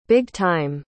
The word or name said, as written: big-time